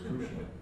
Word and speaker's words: Krishna: So, you know, we're getting to be a big piece of India where it's Krishna. And if you go to Bengal, they make their ahs like os sometimes. So you may hear Krishna.